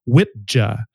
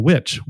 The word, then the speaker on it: wicca: By the way, the word witch, and this is one of the most interesting things to come out of this research, comes from the Old English pronunciation of what we know as Wicca, but it was pronounced back in Old English as Witja. – Hmm. – So Witja, okay.